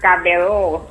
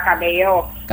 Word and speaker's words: Cabello: Tulisannya tuh Camila Cabello. Bacanya Camila Cabello. – Cabello gitu. Oh, gitu.